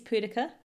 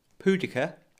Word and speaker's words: pudica: So it's Nepenthes pudica. Might be pronounced like that. – Pudica. – P-U-D-I-C-A. Pudica. – Pudica. Like the old barbarian queen Boudica, if she had an accident and didn't make it to the loo in time.